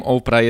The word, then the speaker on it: Oprah: bo Opra pisze się O-P-R-A-H, a w Starym Testamencie jest postać o imieniu Orpa, czyli O-R-P-A-H, ale jej ojciec,